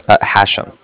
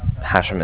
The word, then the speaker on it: Hashem: Hashem. – Can you spell that, please? – Hashem. It's H-A-S-H-E-M, as in mother at the end.